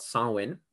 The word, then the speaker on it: Samhain: But so Halloween is based on a festival called Samhain. It's a Celtic festival. and it's not spelled the way it sounds, so it's really confusing.